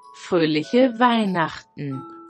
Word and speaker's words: Fröhliche Weihnachten: Fröhliche Weihnachten.